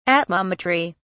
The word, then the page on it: atmometry: British English